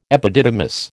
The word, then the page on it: epididymis: American English